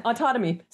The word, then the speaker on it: autotomy: Yeah, autotomy. Sounds like autonomy, oddly enough, but with one letter changed.